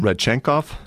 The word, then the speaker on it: Rodchenkov: Rodchenkov.